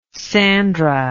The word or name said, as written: sandra